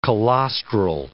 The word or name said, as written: colostral